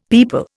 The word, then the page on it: peepul: Indian